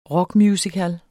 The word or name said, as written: rockmusical